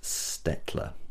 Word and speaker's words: Staedtler: Well, it's Stettler, or Stadler. It's a German brand, Staedtler, which I think is pronounced Stettler.